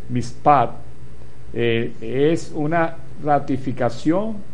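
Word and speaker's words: mishpat: mispat, con acento en la A, M-I-S-H-P-A-T, con acento en la A, mispat, para juicio, y sedek, o T-S-E-D-E-K, para justicia.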